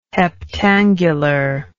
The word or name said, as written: heptangular